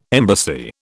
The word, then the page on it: embassy: British English